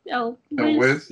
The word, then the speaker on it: Elwes: Carrie Elwes? – Yes.